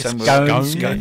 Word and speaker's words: scones: Or as they call them, scones. – Yes, scones. Scones. – Well, I suppose that makes sense because it's spelt stones with a C, so...